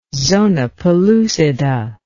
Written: zona pellucida

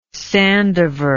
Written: sandiver

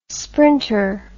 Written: sprinter

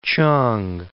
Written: chang

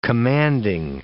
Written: commanding

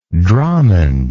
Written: dromond

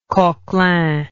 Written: coquelin

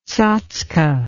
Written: tsatske

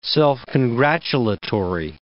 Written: self-congratulatory

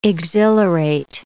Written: exhilarate

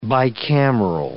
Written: bicameral

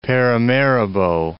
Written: Paramaribo

